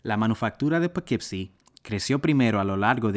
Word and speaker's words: Poughkeepsie: La primera colonización cerca de Fallkill retuvo su nombre indígena de Wappinger, Upuki Ipising, pronunciado Poughkeepsie por los europeos, con el significado de cabaña cubierta en juncos cerca del pequeño lugar de agua.